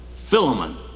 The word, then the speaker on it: Philemon: In South Africa, they pronounced it Philemon. I never heard that till I got to South Africa.